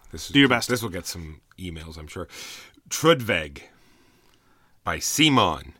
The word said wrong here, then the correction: Trudvang: Trudvang. – I don't know how it's pronounced, but isn't there an N? – There is. V-A-N-G.